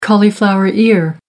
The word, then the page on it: cauliflower ear: American English